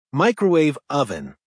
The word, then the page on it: microwave oven: American English